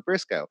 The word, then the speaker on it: Briscoe: Pat Patterson picking out his evening gown for his match later tonight against Gerald Briscoe. Yes, I said that right. Pat Patterson picking out his evening gown for his match against Gerald Briscoe for the hardcore title.